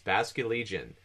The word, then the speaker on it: Basculegion: Um, oh, whew, this is the fish one, the Basculegion. – Yes, Basculegion. – Oh it, my god, it's it sound it sounds like it's spelled.